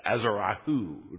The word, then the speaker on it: Azarahu: Actually, the spelling is slightly different in the Hebrew. It's Azarahu would be the best pronunciation of the fourth child.